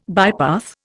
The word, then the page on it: bypath: Indian